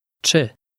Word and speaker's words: Czy: Czy. Czy. Czy. – Note that it starts with a sound that resembles the beginning of the word cherry. Listen and repeat again to check your pronunciation.